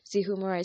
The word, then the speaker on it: Zeehumura: Zihumura is spelled as Z-E-E-H-U-M-U-R-A.